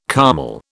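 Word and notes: kümmel: British English

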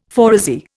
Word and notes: phoresy: Indian

